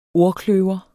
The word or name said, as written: ordkløver